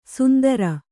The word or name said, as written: ಸುಂದರ